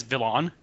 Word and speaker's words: Vilain: Yeah, but it's pronounced French, so it's spelled V-I-L-A-I-N, Villain.